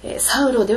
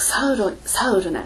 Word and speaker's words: サウル: 英語ではサウルなんです。サウロではなくサウルなんです。なぜかというとヘブライ語でサウルは、サウルなんですがこれをギリシャ語で発音するとサウロとなるので日本語の聖書はどっちも取ってしまってるんですね。